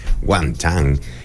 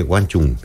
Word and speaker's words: Wang Chung: Marquillo González, espero que hayas disfrutado ahí el tema de Wang Chang, que en realidad se escribe Wang Chung.